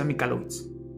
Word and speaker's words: Michalowicz: Se pronuncia Mikalowicz. Se escribe Michalowicz.